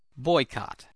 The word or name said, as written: boycott